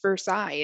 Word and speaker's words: Versailles: There is, in fact, a Versailles, Kentucky, and there is a same spelled named town in Indiana, but it is actually pronounced Versailles in Indiana.